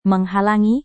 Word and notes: menghalangi: Indonesian

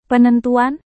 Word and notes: penentuan: Indonesian

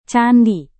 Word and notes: candi: Indonesian

